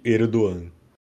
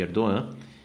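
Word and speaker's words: Erdoğan: E aqui, Aurélio, a gente tem que voltar a falar então sobre aí o presidente Erdogan. – Exato, né? O presidente cujo G a gente aprendeu aí que não se pronuncia.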